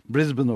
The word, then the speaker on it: Brisbane: Brisbane okunuyormuş öyle bu arada. Mi? Avustralyalılardan dinledim.